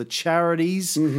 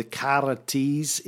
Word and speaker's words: Charites: Three or more goddesses, charm, beauty, nature, human creativity, fertility, known as the Charites or the Charities, if we pronounced it in modern style, but the Charites in Greek.